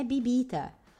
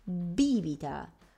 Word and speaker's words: bibita: Attenzione, la pronuncia non è bibita, ok? È bibita.